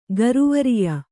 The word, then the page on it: ಗುರುವರ್ಯೆ: guruvarye